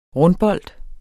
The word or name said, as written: rundbold